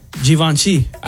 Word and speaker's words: Givenchy: Givenchy. – I've never even heard of that brand. – I get, you're supposed to pronounce it with a Z, according to this. It's Z-H-E-E-V-O-N-S-H-E-E. Givenchy.